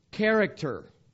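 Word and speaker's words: Character (χαρακτήρ): The word express image comes from one Greek word. And I'll pronounce it to you. Character. Now, what does that sound like? Character. Y'all know Greek now.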